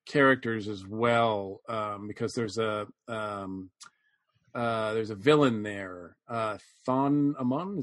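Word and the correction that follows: Thoth-Amon: is that right? Am I pronouncing that right? – Toth Amon? – Yes, yes. Yeah.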